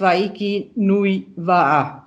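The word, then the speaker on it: Hawaiki Nui Va'a: Das heißt Waiki Nui Waa, wenn ich das richtig ausspreche. – Ja, ist schon richtig.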